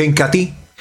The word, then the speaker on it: Incatea: Se escribe Incatea, pero se pronuncia Incati.